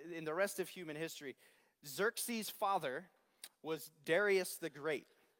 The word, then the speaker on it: Xerxes: This is Ahasuerus. That's the way the Hebrews pronounce his name. So the Jews that were living there at the time pronounce his name Ahasuerus, but Xerxes is how the Greeks pronounced it.